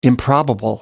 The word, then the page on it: improbable: American English